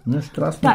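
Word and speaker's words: Strážný: Strażni klingt schon ganz gut. Strażni. – Das Z ist unser stimmhaftes Sch, Garage, ne? Wenn das einen Haken drüber hat.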